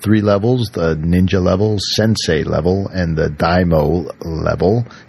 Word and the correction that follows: daimyo: Is that how you pronounce it? No, it's daimyo.